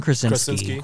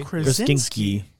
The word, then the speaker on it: Krasinski: John Krasinski. – Krasinski.